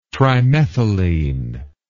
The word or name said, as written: trimethylene